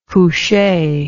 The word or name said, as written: couche